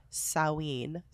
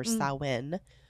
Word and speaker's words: Samhain: It's believed to be the birthplace of the Irish pre-Christian festival of Samhain or Samhain, which we now today know as Halloween. – P.S. And that's spelled S-A-M-H-A-I-N.